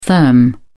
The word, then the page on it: therm: British English